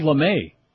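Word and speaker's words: lamé: Ooh, gold lame. I love it. rhymes with gay.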